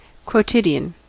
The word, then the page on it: quotidian: American English